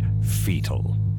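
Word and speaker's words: foetal: Whilst I applaud your use of the proper English spelling of the word, I'm afraid the pronunciation is still simply fetal.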